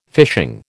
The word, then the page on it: fishing: American English